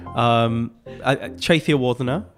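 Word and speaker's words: Chetiyawardana: Close, very much close. It's a difficult surname. I think that's partly where the whole Mr. Lyon thing came from. And even I still get told off by my sister that I'm not saying it properly anyway. Chathi Wardhana. Yeah, the T-I and the D soften.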